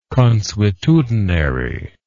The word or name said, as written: consuetudinary